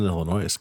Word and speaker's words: Illinois: It's in Illinois, Casey. – No, it's actually in Illinois. – You're missing the S in there. – Yeah, the S is silent there, bud.